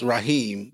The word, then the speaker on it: Raahiim: His, his name is Rahim, but the way it's spelled is R A A H I I M.